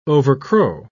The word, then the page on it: overcrow: British English